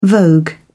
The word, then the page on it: vogue: British English